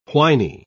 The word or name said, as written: whiny